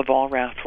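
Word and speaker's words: Vollrath: Yeah, all the Volraths were on it. – Uh-huh. What's your name? – Volrath? – How do you spell that? – V-O-L-L. – Uh-huh. – R-A-T-H.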